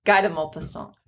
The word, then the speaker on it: Guy de Maupassant: Guy de Maupassant.